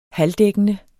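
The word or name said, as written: halvdækkende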